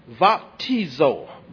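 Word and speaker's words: baptizo (βαπτίζω): And so the Greek word utilizing the modern Greek pronunciation is baptizo. Baptizo. There is no B or beta in the modern Greek pronunciation. Amen. It is vita, not beta. So, baptizo. Here,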